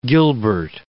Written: gilbert

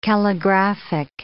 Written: calligraphic

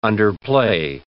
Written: underplay

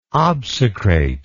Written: obsecrate

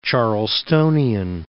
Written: Charlestonian

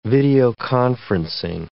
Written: videoconferencing

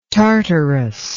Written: tartarous

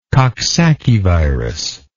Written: coxsackievirus

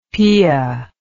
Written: pia

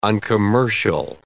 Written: uncommercial

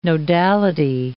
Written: nodality

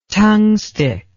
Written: tungstic

